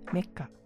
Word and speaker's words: Mecha: Mas ele se pronuncia mecha, né? Vem de mechanical, e mecanicaru, né? A pronúncia japonesa, né, de mechanical, que vem de mecânico mesmo, né? Algo que é, é mecânico. Aí fala mecanicaru, e aí ficou meca, mecha.